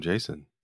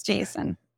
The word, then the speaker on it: JSON: Like the name, Jason. – Jason. – Oh yeah. – My gosh. – It is Jason. It's like, it's the name. according to the creator, it is Jason.